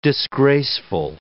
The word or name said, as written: disgraceful